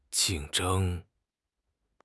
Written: jing4 zheng1

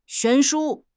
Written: 悬殊

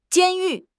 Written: 监狱

